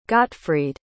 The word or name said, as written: gottfried